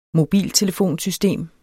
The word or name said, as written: mobiltelefonsystem